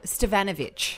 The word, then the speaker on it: Stefanovic: Is that how I say her name? Stefanovkovic. – No, Stefanovic. – Ah, there you go. – Because it's the emphasis that wasn't like Karl Stefanovic's last name, because that's been Aussieified. – She is a writer, that's right, who writes a story and a book called Miss Ex Yugoslavia.